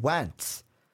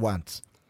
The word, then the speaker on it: Wentz: But Carson Wentz, and I hope I'm saying that right, W-E-N-T-Z, Wentz. – Like Pete Wentz.